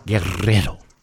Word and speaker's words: Guerrero: I'm Dan Guerrero, or if you can roll your R's, Guerrero.